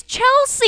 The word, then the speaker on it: Chelsea: Chesley? – Is it Chessie? – It's Chelsea.